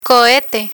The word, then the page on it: cohete: spanish